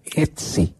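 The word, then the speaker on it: Hezze: Este es volante de marca, es un mediocentro volante de marca, Santiago Etze. Se escribe H-E-Z-E, que era el Olympiacos.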